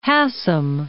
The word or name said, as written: Hassam